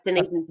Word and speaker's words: Dunedin: I have Dunedin. Dunedin's pretty straightforward. You've got it.